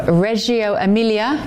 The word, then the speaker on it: Reggio Emilia: She's set to spend 2 days in the northern city of Reggio Emilia, is that how I pronounce it, Greg? – Yes.